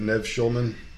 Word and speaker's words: Nev Schulman: How do you say his name? Nev? – Nev Schulman.